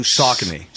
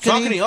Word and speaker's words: Saucony: How do you even pronounce it? The S-A-U... – Saucony. – Saucony? – Saucony, oh, those are great. – That's how you pronounce that? – Yep.